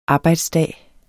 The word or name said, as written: arbejdsdag